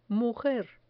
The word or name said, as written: mujer